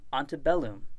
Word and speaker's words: antebellum: The Latin saying for this week is antebellum, before the war. Antebellum. This is the common English pronunciation, and so we're going to use that.